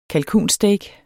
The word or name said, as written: kalkunsteak